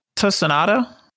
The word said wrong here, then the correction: Tsunoda: Tsunoda. – Tsunoda. – Yeah, silent T.